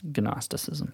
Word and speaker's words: Gnosticism: It's pronounced Gnosticism.